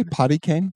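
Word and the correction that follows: Podkayne: Yeah, that's how I remember pronouncing it as a kid. – Oh, that's adorable. That's not how you say it, but... – How do you say it then? – Pod Cane. – Yeah, I was always Potty Cane. P-O-D-E-K-A-N-E, I think, right? – Potty Tell cane.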